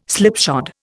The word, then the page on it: slipshod: Indian